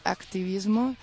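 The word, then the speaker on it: hacktivismo: Yo creo que se lee activismo, que suena como activismo, pero se escribe con la H.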